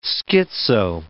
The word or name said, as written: schizo